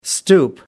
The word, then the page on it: stoop: American English